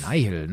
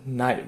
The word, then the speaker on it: Nihil: Und von daher finde ich, haben die äh, Nihil oder Nihil, wie auch immer man sie jetzt aussprechen mag. Ja, Nihil, ne? Noch. – Nihil, ja genau, Nihil.